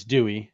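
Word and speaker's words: Dewi: For Wales, Dowie Lake. No, it's Dewey. Sorry.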